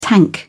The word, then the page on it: tank: British English